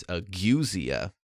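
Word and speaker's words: ageusia: And then for taste, some people who can't detect any taste, which is called, oh, that's a terrible word, agusia. It's A-G-E-U-S-I-A, and I know the pronunciation because it's right there. It's agusia.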